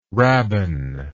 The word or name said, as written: rabban